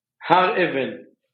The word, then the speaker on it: Hareven: Har Even.